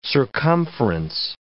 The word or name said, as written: circumference